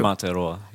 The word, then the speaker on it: Aotearoa: Now, who is the one from, how do you pronounce it, Aotearoa, besser bekannt als Neuseeland? Aha, that's you. – I'm from Aotearoa, yeah.